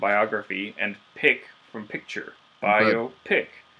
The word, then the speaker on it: biopic: Biopic. Biopic. Like pick is the word there for picture, and bio is biography.